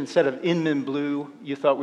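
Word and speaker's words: YInMn: Now it's pronounced Inmin blue. – It's Inmin blue, that's why.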